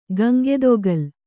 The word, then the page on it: ಗಂಗೆದೊಗಲ್: Gaŋgedogal